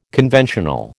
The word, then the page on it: conventional: American English